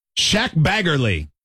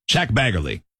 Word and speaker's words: Shaq Baggerly: Shaq Baggerly. Shaq, like a shack. – Mm-hmm. – And then Baggerly, like Baggerly. It's spelled like you'd expect. Shaq Baggerly.